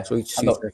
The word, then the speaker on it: Souček: You know, I know they've got um is it pronounced Soiche West Ham? Uh, yeah. Yeah. – Soiche, yeah.